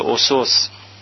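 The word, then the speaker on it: أسس: هر دو اینا میشه اسس، که معمولا دیدم غلط تلفظ میشه.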